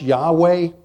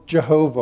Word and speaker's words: Yahweh: It can be pronounced Yahweh or Jehovah or Yehovah.